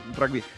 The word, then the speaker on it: rugby: Le dice rugby, ¿viste? Allá los gallegos te pronuncian las letras como son, no tienen mucha vuelta como nosotros, que andamos, ¿viste? Que a veces le mandamos inglés con algo de español.